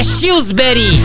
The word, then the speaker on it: Shrewsbury: É Shrewsbury.